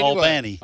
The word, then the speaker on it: Albany: And they said, no, you're not saying it right. So anyway, Albany.